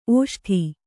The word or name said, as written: ಓಷ್ಠ